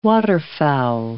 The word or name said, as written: waterfowl